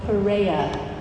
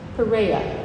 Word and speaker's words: Perea: This region beyond the Jordan River was called Perea, or Perea, depending on how you'd like to pronounce it. Perea. That's its first century name.